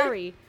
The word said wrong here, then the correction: Kari: It's Kari. Here's the way you know. My first anime boss, and she was a guest there, and I went to her panel. She taught us how to pronounce her name. Just think of Kari like Ferrari.